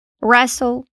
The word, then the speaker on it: wrestle: Palavras como wrestle, lutar, rap, embrulhar, wrist, pulso e write, escrever. Em nenhuma dessas a gente pronuncia o W.